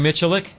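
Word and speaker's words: Miculek: Mike Milik. – Jerry Michalik.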